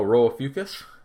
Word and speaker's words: Rho Ophiuchi: Oh, Rho of Fucus? – Yeah, that was pretty good.